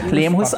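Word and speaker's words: Leemhuis: Und als ersten Beitrag haben wir, das langsam schon Tradition, den Thorsten Lehmhuis, richtig diesmal aus, ja, Lehmhuis ausgesprochen.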